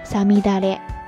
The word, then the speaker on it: 五月雨: 日语呢，读作サミダレ。